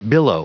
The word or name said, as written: billow